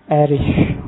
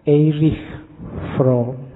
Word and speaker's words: Erich Fromm: Membacanya kalau bahasa aslinya karena lahir di Jerman, orang Jerman membacanya Erich Fromm. Tapi nanti dia saat sukses hidupnya di Amerika. Orang Amerika membacanya lebih suka pakai Eric. Jadi belakangnya K. Makanya kita membacanya santai aja. Tidak harus kaku, harus Eric. Nanti lidahmu melengker. Biasa aja dibaca Eric from Seorang tokoh yang dikenal uh,